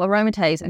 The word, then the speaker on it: aromatase: Oh, aromatase inhibitors.